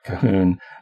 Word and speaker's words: Colquhoun: By the way, it's not spelled Kahun. It's spelled C-O-L-Q-U-H-O-U-N. So that's how you know how to spell it, Kahun.